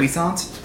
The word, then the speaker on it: puissant: Encounter poisson? – Huh? – I'm not sure to pronounce that word. – Puissant? – Puissant? Perhaps. – Yeah, it's puissant. Puissant.